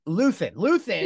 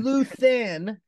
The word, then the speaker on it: Luthen: How do you pronounce Luthen? – Luthen. – Yeah, it's kind of like Luther, but with an N. – Luthen.